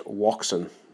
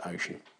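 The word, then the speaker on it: Wouxun: although in this country it's commonly pronounced Woxun, or Wuxun, because it's spelled W-O-U-X-U-N. I don't know how you get Ocean out of that, but anyway, I'm told that that is the correct pronunciation. According to Blind Drummer anyway. And Ace United did see it on another website as well. It's definitely supposed to be pronounced Ocean. Anyway, I digress.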